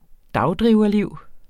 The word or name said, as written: dagdriverliv